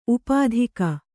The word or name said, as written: ಊಪೋದಿಕೆ